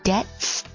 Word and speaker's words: debt: The company crashed with debts of £50 million. Debt 这个名词，注意它的 B 呢是不发音的。